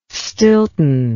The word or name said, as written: stilton